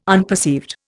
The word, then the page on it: unperceived: Indian